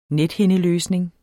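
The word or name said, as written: NETHINDELØSNING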